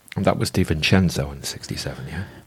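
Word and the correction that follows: De Vicenzo: DeVincenzo, apparently that's how you pronounce it. DeVincenzo.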